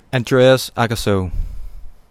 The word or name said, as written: Andreas Aakerso